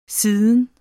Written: siden

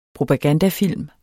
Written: propagandafilm

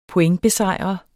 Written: pointbesejre

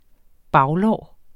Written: baglår

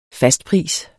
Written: fastpris

